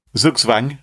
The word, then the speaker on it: Zugzwang: Zugzwang? – Zugzwang.